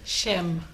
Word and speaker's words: Shim: Shim. – Shim from Phoenicia Honey. S-H-I-M? – Yes. – S-H-I-M. S-H-I-M.